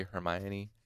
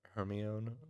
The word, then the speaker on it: Hermione: But yeah, trying to say Hermione uh, I didn't realize we didn't realize that it was uh, pronounced like Persephone um, for a long little while, so we were pronouncing it with like like Hermone or something like that.